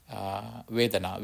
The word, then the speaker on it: vedana: The first one, if you want, the first one is global form, that is rupa, R-U-P-A, rupa, mean physical body, rupa. Second one is water bubble, vedana, V-E-D-A-N-A, vedana. Because during the last, I used too much Pali, that's why I purposely avoid Pali terms. Vedana, V-E-D-A-N-A, vedana, that is feelings.